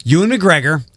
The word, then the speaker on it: Ewan McGregor: Ewan McGregor, did I say that right? – Yeah. – Ewan McGregor is in talks to return as Jedi Master Obi Wan Kenobi in a series for Disney Plus.